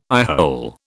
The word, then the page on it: eyehole: British English